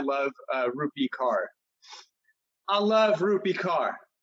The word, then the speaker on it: Rupi: I love that Rupi rhymes with poopy.